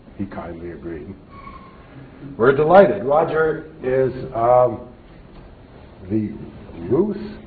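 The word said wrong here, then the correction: Rouse: is that how you pronounce it? – Russ.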